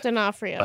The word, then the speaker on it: D'Onofrio: Because I was like, that's not Vincent D'Onofrio. I don't know how you say it. – D'Onofrio.